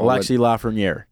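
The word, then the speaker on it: Alexis Lafrenière: So Alexi Lafreniere.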